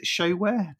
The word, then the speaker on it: Showhere: Tech startup, I mean, it sounds like you're in a tech boom, but then you set up, is it Showhere? Did I pronounce that? – Sure, yeah, exactly.